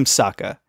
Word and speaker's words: Sokka: I'm Sokka. It's pronounced with an Akka, young ladies. I rocked ya."